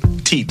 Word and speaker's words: Teat: Teat. – Now, how do I pronounce the name of the nursery? That's a family name, right? – Yeah, it's Teat. – Teat? – Teat. Teat Nursery.